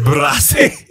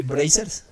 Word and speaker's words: Brazzers: Bracer. no se dice así. Bracers. Sí, sí, así se dice.